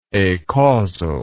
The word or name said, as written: acausal